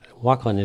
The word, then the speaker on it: Walklin: And people out there in radio land would have no way of knowing, but there's Walklin is an English name, but there's an L on either side of the K, and people drop one or the other.